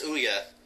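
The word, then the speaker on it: Ouya: I'm not quite sure how to pronounce that. I even looked up a pronunciation. – I'm pretty sure it's Ooyah.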